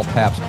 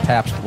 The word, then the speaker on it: Pabst: I am your host, Paul Pabst. Pabst, like the beer, P-A-B-S-T. You know, when I'm in Chicago or I'm in Wisconsin or Indiana or Milwaukee especially, if I say my name, I'm like, oh, Pabst, great.